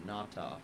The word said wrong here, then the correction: Latu: coming back. He's just now getting back to practice, big tight end. – Latu, yeah.